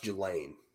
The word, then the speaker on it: Ghislaine: So it's pronounced Ghislaine. Ghislaine Maxwell. – Ghislaine. It's Ghislaine.